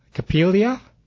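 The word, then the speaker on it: Coppélia: And the YMCA Dance Studio has an open auditions for, I'm not sure if I'm going to say this right, Capelia? – Mm-hmm.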